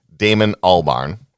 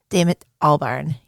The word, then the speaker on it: Albarn: I always mispronounce his name. Damon Albarn. – Damon Albarn, yes.